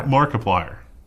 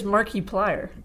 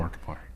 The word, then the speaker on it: Markiplier: How do you pronounce this guy's username? – Markiplier. – Oh, I read it as Markiplier. – That's terrible. – Like, I put an emphasis on the I. – I hate notes. That. – Definitely Markiplier.